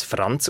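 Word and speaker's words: Franc: In dem Fall müssen wir annehmen, dass der Name ursprünglich als Franz ausgesprochen worden wäre, weil ein C in der slawischen Sprache als Z ausgesprochen wird.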